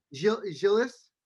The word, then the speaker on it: Gilles: and that's Gilgeous Sen. I believe I said his name correctly. Gilgeous Sen. Yeah. – Gilles. We don't say the S at the end. – Oh, no S, so it's Gilles Sen.